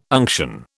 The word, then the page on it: unction: British English